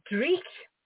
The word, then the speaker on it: Dreich: Well, goodness, if they go to the publisher, and I'm going to use another Scottish word here, so you'll like my pronunciation. The publisher's sure. name is Dreich, D-R-E-I-C-H.